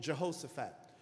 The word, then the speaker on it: Jehoshaphat: It says messengers came and told Jehoshaphat. Now, if you're pronouncing it correctly, it's Jehoshaphat.